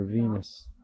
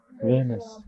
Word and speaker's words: Venice: how you say it, Venice or Venus? Venice, yeah. It's on water, you know.